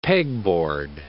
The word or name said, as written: Peg-Board